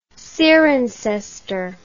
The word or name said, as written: cirencester